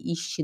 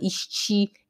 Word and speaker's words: ist: alfi, isti. Eu também falava muito assim, né? Alfi, isti. Não é alfi, é alf. Não é isti, é ist.